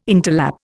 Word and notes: interlap: Indian

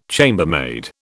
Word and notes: chambermaid: British English

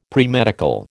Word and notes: premedical: American English

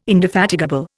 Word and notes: indefatigable: Indian

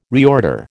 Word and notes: reorder: American English

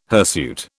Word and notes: hirsute: British English

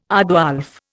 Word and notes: aardwolf: Indian

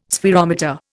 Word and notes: spherometer: Indian

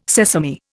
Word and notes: sesame: Indian

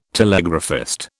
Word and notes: telegraphist: British English

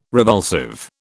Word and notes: revulsive: British English

